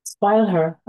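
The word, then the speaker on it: Spieluhr: And then we get to number seven, which in English is called Music Box, and you can say the German pronunciation. – Spoil her. I think I'm saying it correctly.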